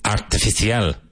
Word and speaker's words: artificial: Artificial. Artificial. Artificial. So the middle sound is fish, like pescado. Artificial. Even though it's spelled artificial. Artificial.